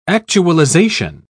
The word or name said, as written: actualization